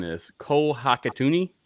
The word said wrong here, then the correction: Hikutini: Did I say that correctly? – I think it's Hikutini. – Hikutini.